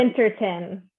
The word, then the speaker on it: Vintertun: Am I saying it wrong? I'm always butchering names. I'm sorry. – Vintertun.